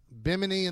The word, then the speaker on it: Bimini: Bahimini, right? Am I saying it right? – Bimini in the Bahamas. – No, I guess I wasn't.